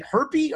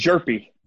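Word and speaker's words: Gerpy: Is it herpy or herjerpy? I would hope for his sake. – It's jerpy. – Jerpy. Okay. I would hope for his sake that that was the case. Silent J would be tough for our guy there.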